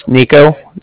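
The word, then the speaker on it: Niko: Again, my name's Nico. That's N-I-K-O.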